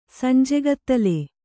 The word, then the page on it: ಸಂಜೆಗತ್ತಲು: Kannada